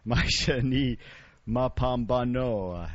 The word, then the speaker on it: Maisha Ni Mapambano: I said it wrong again. Maisha Ni Mapambano. And that's from the CD Amigo on the Clifford Lugard label.